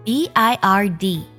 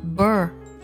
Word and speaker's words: bird: 比如，我们来看几个单词：小鸟 b i r d 读作 bird bird；学期 t e r m 读作 ter ter term；世界这个单词 w o r l d 读作 world world world world。